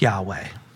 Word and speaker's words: Yahweh: Well, that's the English translation of Yahweh, or actually the four consonants, WH, thought to be pronounced Yahweh.